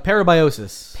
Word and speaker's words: parabiosis: can you pronounce that? – Parabiosis. – Parabiosis. Thank you. I was actually kind of curious how that was said out loud. Yeah. It has a very odd vowel structure.